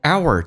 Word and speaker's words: our: 我们来看一下这边，our 在口语化当中弱化现象一般会说成 er，打开口腔一点，往回卷舌，在美式发音叫卷舌音，叫 er。 Our minds，而且用降调，非实义词汇。 Our minds， r 在口语化当中一般说成 er，轻轻点一下，往回卷舌 er。